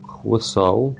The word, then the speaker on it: хвысау: А, хвысау пишется Х, потом в, потом Y.